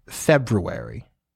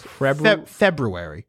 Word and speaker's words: February: You know, technically, it's pronounced February. It's technically pronounced February. – February. – But it's February. I always say February.